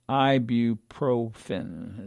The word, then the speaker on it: ibuprofen: Think of it as Advil, Motrin, ibuprofen is how you pronounce it.